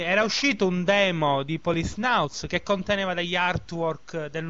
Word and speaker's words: Policenauts: Prima questione, come si pronuncia Policenauts? – Policenauts. – Policenauts. – Io penso Policenauts. È tipo, Psychonauts, sì, però con la police davanti. Quindi Policenauts. – Esattamente.